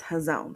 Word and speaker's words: hazon: So vision, the Hebrew word for that is hazon. Now, you all get on the Blue Letter Bible app and hear how it's pronounced because I might be making that up. It's pronounced hazon.